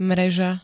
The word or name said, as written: mreža